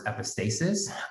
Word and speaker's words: epistasis: Yeah, so the common pronunciation of that word is epistasis.